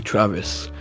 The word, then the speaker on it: Travis: So, my real name is actually Travis.